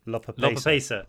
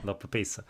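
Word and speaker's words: lopapeysa: I was glad to hear that the Lopesia, was that how we pronounce it? The jumpers that we got when we were in Lopapesa. – Yeah. – Lopapesa.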